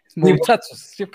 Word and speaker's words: Moutsatsos: Sí, que eh, siempre es, se pronuncia así como muchachos. Es Moutsatsos.